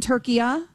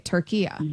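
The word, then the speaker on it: Türkiye: So you're saying, honey, you're saying that in that language, it's always been Turkiya? – That's what he's saying, yes.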